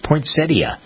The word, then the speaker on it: poinsettia: Poinsettia, some people pronounce it, but it's poinsettia. That's the way the dictionary tells us. – There's a syllable in there. – Right, exactly. Yeah, there's a T-I-A in there, poinsettia.